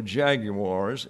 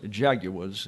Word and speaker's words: jaguars: They're easy prey for jaguars. Jaguars is the way the Brits say it. Jaguars or jaguars and other predators.